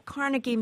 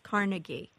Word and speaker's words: Carnegie: But you'll still find a lot of, like Carnegie Mellon. It's always pronounced Carnegie. But you still will find people who say Carnegie. – Me. – You say Carnegie? – Yes. – Right. That's the original Scottish pronunciation.